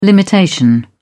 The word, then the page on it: limitation: British English